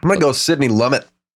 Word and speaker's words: Lumet: Did I pronounce the Lumiere? T? – Sidney Lumet.